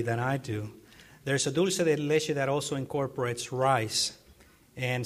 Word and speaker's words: dulce de leche: Regarding desserts, what is dulce de leche, and how can I make it from supermarket ingredients? And I hope pronounce that correctly for us, if you would, too. – Yeah, dulce de leche.